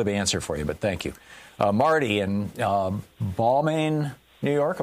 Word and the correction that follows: Baldwin: Am I saying that right, Marty? – Baldwin is fine. – Oh, Baldwin. Okay.